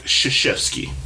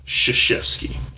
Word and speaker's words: Krzyzewski: But, do you know what his name is? It's pronounced Mike Krzyzewski. Krzyzewski. Okay? – Okay. – His last name is spelled, however, K-R-Z-Y-Z-E-W-S-K-I.